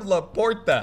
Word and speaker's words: LaPorta: Sexiest guy in the league, Sam LaPorte. – No. He's not even saying his name right. – What's his name? – Sam LaPorta.